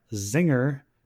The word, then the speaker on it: Czinger: Because that's the amount of time that you have to get to 60 miles an hour in the 2021 Zinger 21C Hybrid Supercar. That's spelled C-Z-I-N-G-E-R, 21C.